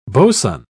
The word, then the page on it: boson: British English